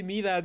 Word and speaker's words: Midas: Y sí, o sea, es como de, no se dice Maidas, pero así bien enojados, como si hubiera insultado a su mamá, ¿no? Del que me comenta eso de, no se dice Maidas, se dice Midas, ni sabes hablar, no hagas videos, me desuscribo y ojalá te borren el canal.